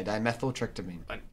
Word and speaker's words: dimethyltryptamine: All right, if I could pronounce it right, that's how you say it. Dimethyltryptamine. – Yeah, dimethyltryptamine. – Dimethyltryptamine. Okay, yeah, you got it.